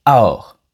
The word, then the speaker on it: alors: Vous avez entendu Alors s'est transformé en aor. Le L du mot alors a complètement disparu et on prononce donc seulement aor. Et ce mot est vraiment prononcé très rapidement.